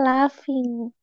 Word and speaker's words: laughing: Kok laughing Itu bacanya laughing. – Masa love? – Jadi kayak wow. – in, yang terakhir, yang berakhiran GH itu pasti selalu dibilang F. F kayak enough in enough kan itu kan tulisan enough